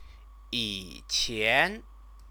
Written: yiqian

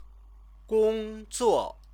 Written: gongzuo

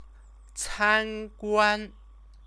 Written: canguan

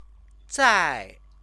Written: zai

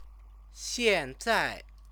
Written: xianzai

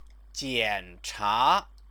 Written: jiancha

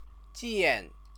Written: jian

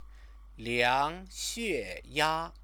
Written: liangxueya

